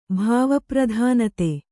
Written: ಭಾವಪ್ರಧಾನತೆ

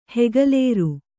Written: ಹೆಗಲೇರು

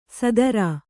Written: ಸದರಾ

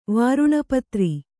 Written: ವಾರುಣಪತ್ರಿ

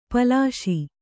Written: ಪಾಲಿಸಿ

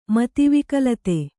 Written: ಮತಿವಿಕಲತೆ

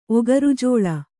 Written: ಒಗರುಜೋಳ

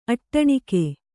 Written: ಅಟ್ಟಣಿಗೆ

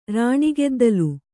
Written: ರಾಣಿಗೆದ್ದಲು

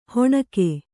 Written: ಹೊಣಕೆ

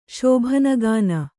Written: ಶೋಭನಗಾನ

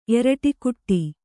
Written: ಎರಟೆಕುಟ್ಟಿ

